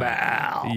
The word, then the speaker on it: Baal: And I don't know if I pronounced that correctly. – Yes. – There's different ways to pronounce it. We were talking about Baal. – Yes. Yeah. I was like, Baal. Is that a good way to do it? But so Baal is B-A-A-L is the Canaanite god.